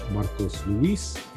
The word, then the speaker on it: Marcos Luis: One Mic Night is spelled O-N-E-M-I-C-N-I-T-E. And you can follow me at Marcos Luis, M-A-R-C-O-S-L-U-I-S, on every platform.